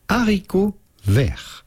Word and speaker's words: haricot vert: Like we would say it in France, we would say haricot vert. – Oh. – Haricot being French for beans and vert for green. Haricot vert.